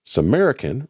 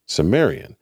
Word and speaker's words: Sumerian: There seems to be two versions on existence of the introduction to this episode, One where Vic Perrin says Samarican rather than Sumerian, and the other one where he pronounces it correctly.